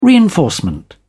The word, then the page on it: reinforcement: British English